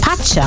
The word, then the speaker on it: Pacha: Sag mal, ähm, heißt das Pascha oder Pacha oder wie spricht man Pacha. – das? – Pacha. Pacha. Ja, weil das ist ja das legendäre Pacha, ist ja direkt um die Ecke.